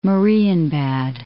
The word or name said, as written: Marienbad